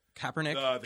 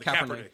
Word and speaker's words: Kaepernick: The Kaepernick. Kaepernick.